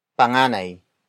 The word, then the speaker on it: panganay: The next word is panganay. Panganay. Panganay. Panganay is spelled as P-A-N-G-A-N-A-Y.